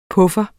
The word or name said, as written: PUFFEREN